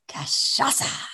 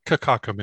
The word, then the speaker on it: Cachaça: Cachaça. – Cachaça. – You know, it's not Kakaka, Mindy.